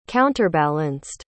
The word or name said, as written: counterbalanced